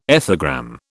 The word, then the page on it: ethogram: British English